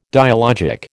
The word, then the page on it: dialogic: American English